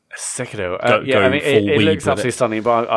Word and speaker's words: Sekiro: Probably, would you pronounce it Sekiro or Sekiro? – It'd be Sekiro, yeah. – Sekiro, yeah. Okay, yeah, I thought I was doing it wrong.